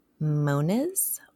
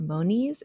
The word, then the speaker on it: Moniz: Dantiel W. Moniz or Moniz.